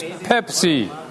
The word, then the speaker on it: Pepsi: Mucha gente lo pronuncia así, ¿eh? Pepsi. Pepsi. Pero es Pepsi.